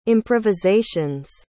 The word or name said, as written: improvisations